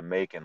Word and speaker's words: Bacon: It rhymes with Macon, like Macon, Georgia.